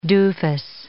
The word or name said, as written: doofus